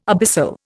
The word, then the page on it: abyssal: Indian